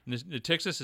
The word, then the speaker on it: Natixis: Natixis, is that how you say it? – Yeah.